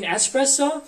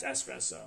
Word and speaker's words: espresso: And I said, and I told her, I said, you mean espresso? And she was like, she gave me like a look. I was like, yeah, it's espresso.